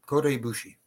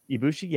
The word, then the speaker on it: Ibushi: Is it Ibushi? I don't. – Kota Ibushi. – Ibushi? Yeah, I'm saying it right.